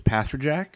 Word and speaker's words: Pasterjack: Exactly like it's spelled, which is Pasterjack.